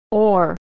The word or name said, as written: or